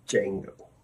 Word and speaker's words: Django: Django. – The D is silent. Sorry.